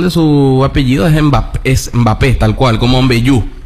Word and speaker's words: Mbappé: cuando en realidad es Mbappé, como Mbeyú.